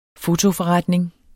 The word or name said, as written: fotoforretning